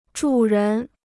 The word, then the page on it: 主人: zhǔ rén